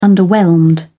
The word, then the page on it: underwhelmed: British English